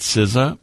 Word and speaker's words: SZA: You pronounce it SZA, but it's spelled S-Z-A.